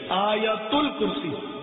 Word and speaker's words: آیت الکرسی: اسی طرح آیت الکرسی، جس کو عوام آیت الکرسی بولتے ہیں، تلفظ صحیح آیت الکرسی،